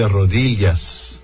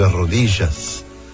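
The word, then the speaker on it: rodillas: A Nátia diz de rodilhas e não de rodijas, e por aí vai.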